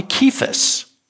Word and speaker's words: Cephas: "We're going to call you Cephas." That's how you pronounce it, by the way. It's a Greek word, and it means Peter.